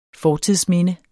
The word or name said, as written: fortidsminde